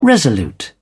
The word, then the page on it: resolute: British English